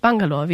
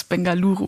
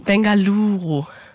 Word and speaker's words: Bengaluru: Wobei, es heißt nicht Bangalore. Wie spricht man es nochmal aus? – Also offiziell heißt es Bengaluru. – Bengaluru. Okay.